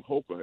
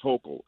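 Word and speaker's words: Hochul: We can't even pronounce her name. Hoka. It's pronounced Hokel.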